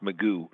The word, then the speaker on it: Magoo: Offensively, I'm going to have to go with Alex Magoo, though, at the and it looks like Macau or something like that, but it's pronounced Magoo, and he has got a 17-8 ratio this year, almost 3,000 yards.